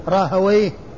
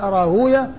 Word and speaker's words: راهويه: أما اللغويون فيأتون به مختوما بويه، الواو مفتوحة والياء ساكنة والهاء بعدها ساكنة، زنجويه، راهويه، وغير ذلك ما كان من هذا القبيل. وأما المحدثون فيقولون وينطقونه فيقولون راهوية وزنجوية وغير ذلك وما أشبه ذلك، واللغويون يجعلونه من قبيل ما هو مختوم بويه، من قبيل ما هو مختوم بويه.